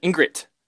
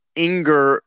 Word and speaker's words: Ingrid: It sounded like you're saying Inger, like, so, I don't know.